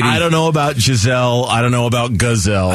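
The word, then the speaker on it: Gisele: It's Giselle. – Who is? Am I mispronouncing it? Yes, it's not Giselle. – She doesn't have horns. – It's Giselle. She's not fast like a gazelle.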